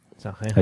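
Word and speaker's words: Sanxenxo: Pero bueno, yo esos lacitos los he comido en Sanjenjo. – Sanjenjo. – Allí en Galicia. Sí. – ¿Cómo pronunciáis de bien, eh? Las jotas fuertes.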